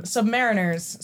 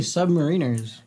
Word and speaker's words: submariner: I was gonna be submariners. – So some people pronounce it submariner, but I grew up in a Navy town and my father was on submarines and he always said submariner.